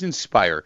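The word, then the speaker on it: Inspier: Want to make sure because I keep I, it's it's pronounced inspire, but the spelling's a little bit different.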